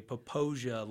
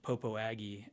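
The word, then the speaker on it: Popo Agie: We tell all our students if they want to sound like a local, they have to say Popoja like Fantasia. – Okay. – And not Popo Aggie as it's spelled.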